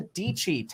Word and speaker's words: Dietschy: We're going to talk about Tesla, Bitcoin, tech, and real estate, because we are talking to Sara, Dietschy, tech YouTuber, rhymes with peachy, and we're going to be talking about all of these topics.